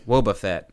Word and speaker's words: Wobbuffet: Wobbuffet.